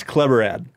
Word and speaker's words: Chleborad: Was that close or no? – Totally butchered last time. Well, yeah. – what is it? – It's Kleberad.